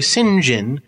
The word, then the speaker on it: St. John: It's just when I see it written out, it looks like St. John. So. – Yes, it's St. John. You know it's pronounced St. John.